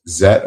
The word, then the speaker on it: Zett: It says Ultraman Z, but it will be pronounced Zet apparently. – Well, it's Fine. more than likely Ultraman Z because that's how it's said in other countries. – Right, but it is spelled Z E T T.